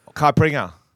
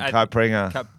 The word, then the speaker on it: caipirinha: Caipirinha. – What? – It's a Brazilian drink. Caipirinha.